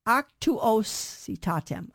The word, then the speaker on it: Actuositatem: Apostolicum Actuositatem, Actuositatem, Actuositatem. By the way, in Latin, there's no I sound, no short I. The I in Latin says E. It's a long E sound, okay?